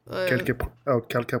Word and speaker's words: Quelques: Spell it. – Q-U-E-L-Q-U-E-S. Quelque? – Quelque.